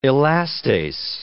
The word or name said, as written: elastase